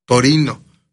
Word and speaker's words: Torino: Es del, fíjate que nosotros decimos esa palabra en español y decimos Turín, pero en Italia ellos se llaman, cuando lo pronuncian, Torino, no dicen Turín.